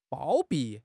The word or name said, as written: 包庇